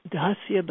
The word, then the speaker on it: dasya: And dasya bhakti, it's a long A, so it's spelled D. I spell it D-double-A-S-Y-A, It's Dasya Bhakti is active.